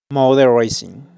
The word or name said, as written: Automovilismo